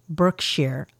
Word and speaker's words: Berkshire: It is in Berkshire County. That's pronounced Berkshire. Okay.